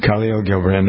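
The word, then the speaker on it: Kahlil Gibran: Here's, remember what, you probably none of you remember Kale Gilbrand. Do you remember him? – Who? – Khalil, some people pronounce it. Khalil Gilbrand.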